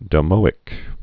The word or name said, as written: domoic acid